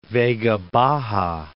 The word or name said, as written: Vega Baja